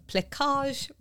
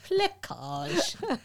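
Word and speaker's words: plaçage: I have to say it like that because I had to look it up. And I sat there and listened to the pronunciation like 10 times. I was like, plecage.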